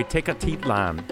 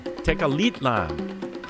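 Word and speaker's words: Tecalitlán: Mariacho Vegas to Tenochtitlan. I'm sorry, Tecalitlan. Tecalitlan. Ha! I managed to mispronounce it three times, and there was a pronunciation guide here.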